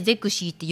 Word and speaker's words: ゼクシィ: ゼクシー